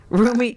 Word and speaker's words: Rumi: Yep, Rummy. – R-U-M-I. Rummy. – That's going to get mispronounced a lot. Rummy. Roomy.